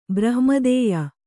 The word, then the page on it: ಬ್ರಹ್ಮಾದೇಯ: brahma'dēya